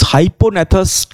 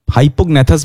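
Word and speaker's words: hypognathous: And the last but not so, yes, what I was forgetting was this hyponathus type. Hypognathus bhi bol sakte ho, hyponathus bhi bol sakte ho, because G is silent here.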